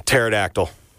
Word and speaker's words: pterodactyl: Pterodactyl. – Very confident. Very, very correct. That silent P there for pterodactyl.